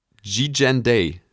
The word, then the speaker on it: Gigandet: Das steht hier in, in Lautschrift daneben. Cam G-Gen Day.